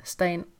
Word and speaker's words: Stein: Stein. – Stein. Amanda Stein. Mm. – mm det är belgiskt. Så... Mm. Eller ja, flamländskt till och med.